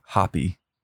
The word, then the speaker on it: Hoppe: We had a question about Matthew Hoppe. Yes, the winger. – We believe that that's how you pronounce it.